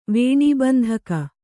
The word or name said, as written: ವೇಣೀಬಂಧಕ